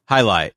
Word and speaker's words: jai alai: And then he talks about Hailai. Am I pronouncing that right? – Hailai. – Yes. – J-A-I-A-L-A-I.